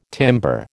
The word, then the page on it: timbre: American English